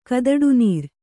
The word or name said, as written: ಕದಡುನೀರ್